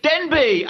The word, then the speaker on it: Denbigh: Denbigh. How do you say that? In Northern Wales. – Denby. Absolutely. Hi, Paul. Yes, Denby? – I am, actually. It's a place called Denby in Wales.